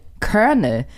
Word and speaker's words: Colonel: Und viel krasser ist Colonel, was nämlich Colonel geschrieben wird. Stimmt, ja. Und darauf zu kommen, dass Colonel Kernel. Mhm. – Weil Kernel dachte ich halt sehr, sehr lange wird K-Ö-R-N-E-L.